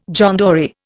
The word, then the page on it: John Dory: Indian